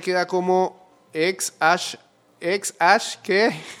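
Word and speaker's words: æ: Ah, espérate, eh, el A y la E se pronuncian ash.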